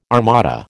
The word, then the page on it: armada: American English